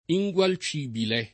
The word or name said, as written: ingualcibile